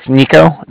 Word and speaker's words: Niko: So it's Nico, N-I-K-O. – Okay. – With the Bank of Cardiff, and that's spelled C-A-R-D-I-F-F, as in Frank.